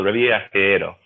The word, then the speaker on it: Revillagigedo: The actual archipelago is called Revillagigedo.